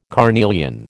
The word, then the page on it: carnelian: American English